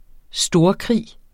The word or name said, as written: storkrig